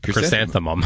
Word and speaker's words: chrysanthemum: Yeah, chrysanthemum. – Chrysanthemum. We know now it ends. It starts with Chris, and it ends with mum.